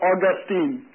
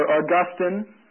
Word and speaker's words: Augustine: I like a story that's told about Augustine, or Augustine, however you choose to pronounce it.